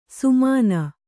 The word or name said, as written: ಸುಮನ